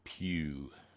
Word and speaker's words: PewDiePie: Rhymes with cutie pie, Asa tells me. PewDiePie.